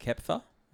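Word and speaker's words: Köpfer: So it was a match between Zverev and Kepfer, if I pronounced that right, at Acapulco, I believe. did I get that right? – Yeah.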